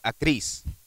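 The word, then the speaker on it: akris (ἀκρίς): Now, the word locust in the Greek is pronounced akris.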